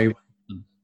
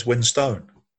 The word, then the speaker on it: Winstone: So I say Winston. – Yeah, well, I say Ray Winston, but when I've heard him on telly, he says Winstone.